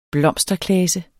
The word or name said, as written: blomsterklase